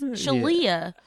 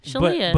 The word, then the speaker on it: Shelia: Shelia? No, it's Shelia. Yeah. Leah, L-I-A, Shelia.